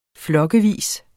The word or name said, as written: flokkevis